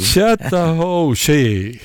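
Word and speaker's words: Chattahoochee: Alan Jackson, hur uttalar man chatta? Hej. – Chatta ho she.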